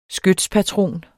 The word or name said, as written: skytspatron